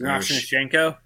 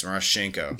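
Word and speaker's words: Miroshnichenko: God, I can't pronounce it. – Marashenko. – Yeah. Yes, Marashenko.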